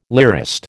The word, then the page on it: lyrist: American English